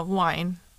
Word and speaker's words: wijn: How would you say W-I-J-N? I mean, J-N. – Yeah, wine. – Wine? Oh, okay.